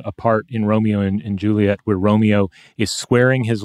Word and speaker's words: Juliet: This is Juliet, or Juliet, right? If you're depending on how you're, what line from the play you're using, sometimes you get to hit two syllables instead of three on that.